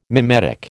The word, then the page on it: mimetic: American English